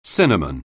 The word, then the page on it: cinnamon: anglais